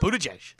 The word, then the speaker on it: Buttigieg: Or how do you pronounce it? Buttigieg. Buttigieg, thank yes.